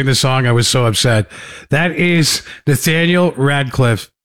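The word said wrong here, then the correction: Rateliff: No, you said it wrong again. Ratliff and the Night Sweats.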